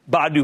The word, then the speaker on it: Badu: Now, this would be an easier call, frankly, if it were just Bumble without the Badu, which, by the way, is pronounced like Scooby-Doo, Badu baggage.